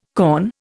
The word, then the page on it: kaon: Indian